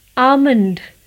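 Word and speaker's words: almond: आमंड बादाम A L M O N D आमंड Now let's see our next word yoke.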